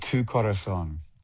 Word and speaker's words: Tu Corazón: You pronounce it Tu Corazón, which is Spanish for my heart or your heart.